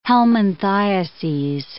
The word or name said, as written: helminthiases